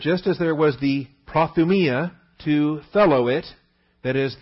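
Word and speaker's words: Prothumia (προθυμία): But look at all the uses here of prothumia. Or prothumia. I like to pronounce my omicrons with a short O sound. Accent is on the iota. Prothumia.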